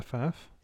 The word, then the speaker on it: Pfaff: How do I pronounce it? Is it Faf? – Oh, P-F-A-F-F. – Yeah, Faf.